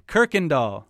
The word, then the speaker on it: Kuykendahl: Kirkendall.